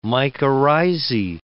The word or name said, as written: mycorrhizae